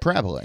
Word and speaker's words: Parabola: Now, that doesn't mean you can't have intensely beautiful flavors coalesce and make you want to continue to revisit them, like the Parabola. I think I cannot pronounce that barrel beer correctly. – Yeah, but Parabola, yeah.